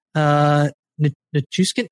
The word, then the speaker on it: Nichushkin: Nichushkin, I'm so sorry. I feel so bad. I have to. – You said it right.